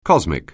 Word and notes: cosmic: anglais

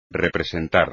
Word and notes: representar: espagnol

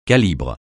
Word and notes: calibre: francais

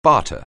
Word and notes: barter: anglais

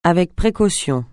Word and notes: avec précaution: francais

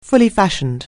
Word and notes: fully-fashioned: anglais

